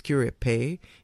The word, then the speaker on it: Curepe: Actually born in Curep, a place called Curep, which I learned now is actually pronounced Curepe.